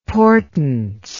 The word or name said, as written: portance